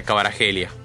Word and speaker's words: Kvaratskhelia: No, Klos lo pronuncia Kvaratskhelia. Yo tengo entendido que es Paratskelia.